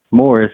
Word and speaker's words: Maurice: The one I mentioned already, Barry, and this guy Maurice, or Morris is how he pronounces it, but it looks like Maurice, M-A-U-R-I-C-E. Morris Manning, he's from Kentucky as well.